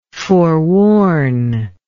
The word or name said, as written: foreworn